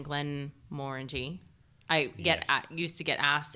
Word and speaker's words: Glenmorangie: So Glenmorangie, confirm how it's pronounced. – It's the only word that rhymes with orangey.